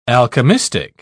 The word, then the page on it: alchemistic: British English